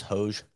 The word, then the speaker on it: Hoge: So I still can't believe Hoagie finished T3 after a first round 78. – That's wild. Please pronounce it correctly. It's Hoge.